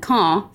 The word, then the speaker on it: Caen: Is it Cannes or is it Cannes? How do I properly pronounce this film festival? – It is the Cannes Film Festival, and Caen, C-A-E-N, is a city that's in Normandy where it's cold and they make apples and cider.